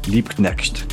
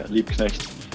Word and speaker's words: Liebknecht: Got something I'm going to mispronounce, I guess. Is it Liebknecht? Barcelona? Liebknecht. There you go. – Yeah, Liebknecht.